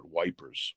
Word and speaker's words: Ypres: It's also been seen to pronounce Ypres. I always say Ypres. What do you say? – It's Ypres if you're a historical snob, but you're right. – Yeah. – And I say Ypres, but the Tommies, the British soldiers, called it Wipers. – it's spelled Y-P-R-E-S.